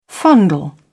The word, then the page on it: fondle: British English